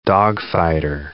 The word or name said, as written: dogfighter